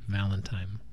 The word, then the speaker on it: Val in Time: But I learned that you just, that's how it's spelled, but you just say it Valentine.